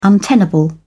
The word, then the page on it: untenable: British English